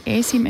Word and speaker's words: ASIM: Er det rigtigt øh, udtalt for øvrigt? – Ja, det er ja, helt, helt det, er korrekt. Jeg var lidt i mm. tvivl, om man sagde ASIM, eller man staver det.